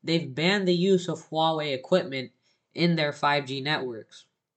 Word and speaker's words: Huawei: I used to pronounce it Hui. And then I started watching Lou Later and Unbox Therapy, and I was quite quickly corrected. Everybody calls it Huawei.